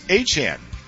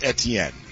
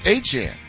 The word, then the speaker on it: Achane: I'm going to try like hell to call new Saints running back this year Travis Achane. Because he says it's not pronounced Etienne. He says it's pronounced Achane. Okay, then let's pronounce it Achane.